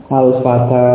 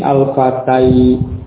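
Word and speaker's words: al-fata (الفتى): Diucapkan dengan alif, al-fata, al-huda, alif seperti al-aswa, ya, walaupun bentuknya ya, ya, bentuknya huruf ya ini. Jadi bukan huruf ya, dan tentunya karena bukan huruf ya, tentunya juga tidak diucapkan dengan ucapan huruf ya. Kalau itu huruf ya, diucapkan dengan huruf ya, akan menjadi al-fatai, al-hudai, tentunya akan semacam, akan menjadi seperti itu.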